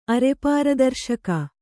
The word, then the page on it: ಅರೆಪಾರದರ್ಶಕ: arepāradarśaka; Kannada